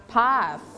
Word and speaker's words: path: Don't forget to pronounce th sound. เสียงเธอเธอเธอเราต้องเอาลิ้นไว้แตะไว้ที่หลังฟันอะไรคะฟันล่างหรือฟันบนฟันบนนะคะ Path.